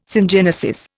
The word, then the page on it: syngenesis: Indian